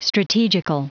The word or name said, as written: strategical